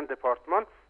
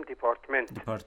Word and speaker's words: دپارتمان: مثلا دپارتمان که در بخش‌های آموزشی دانشگاه هست در ایران میگن دپارتمان و ما در افغانستان میگیم دپارتمنت که به صورت انگلیسی و دپارتمنت است.